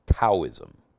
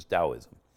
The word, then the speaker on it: Taoism: It's actually a symbol of the religion of Taoism, which most Americans would say Taoism, but it's the right way to say it in Chinese is Taoism.